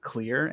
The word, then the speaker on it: Xlear: While I'm not familiar with using, you know, like a no-tear shampoo in the drops, there is a nasal saline spray called Clear, and it's spelled X-L-E-A-R.